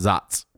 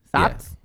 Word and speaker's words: Saaz: It's pronounced no. Zots. – Zots. – Okay, what exactly is that? Because I have not heard of this one yet. – S-A-A-Z? Zots? – Yeah. Zots.